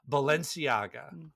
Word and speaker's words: Balenciaga: Balenciagas. What is the brand? – Balenciaga. – You take this one, but please help me out. Balenciaga.